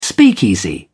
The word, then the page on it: speakeasy: British English